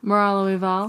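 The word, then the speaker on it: Moralo Eval: Morallo Eval.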